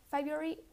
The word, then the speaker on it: February: The R is completely dropped, so we pronounce February without an R, like this February.